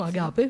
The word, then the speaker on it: Agape: Lei è stata nella staff del campo lesbico di Agape. Ho detto giusto? Agape, sì, perfetto.